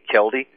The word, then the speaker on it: Kilde: So regarding the plans to microchip newborns, and I'm quoting this article now, Dr. Kelde, I guess is the way to pronounce his name, K-I-L-D-E, Kelde, said that the U.S.